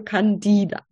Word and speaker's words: Candida: Und die Betonung ist auf dem die. Also Candida. – Richtig.